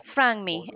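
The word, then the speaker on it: Franmi: My name is Frangmi. It's spelled F as in Frank, R-A-N-M-I. It's like Frank without a K.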